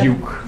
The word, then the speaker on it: Duquesne: le doctorat à l'Université de Duquesne. Je prononce bien, j'espère. – Duke. – Duke. Merci de me corriger.